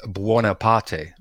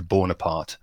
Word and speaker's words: Buonaparte: It's Italian and in fact it's Buonaparte, really. That's how it's supposed to be pronounced. So it's just been, well, presumably anglicized to Bonaparte, but it's certainly not a French name.